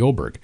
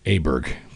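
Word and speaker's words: Åberg: I had Ludvig Oberg, and we called him Aberg back then, but anyways, it's changed. We got the proper pronunciation, and he didn't crack a smile until I asked him to in the interview after the win.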